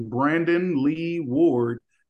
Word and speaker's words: Brandon Lee Ward: Brandon Lee Ward.